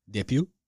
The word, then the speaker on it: Dupieux: So my thing that I'm looking up here is the director, Quentin, I don't even know how to pronounce that, Dupuy, Justin, you'd probably know. – Yeah, I would. – It's spelled Dupieux. – Dupuy? – Sounds good to me.